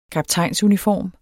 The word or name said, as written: kaptajnsuniform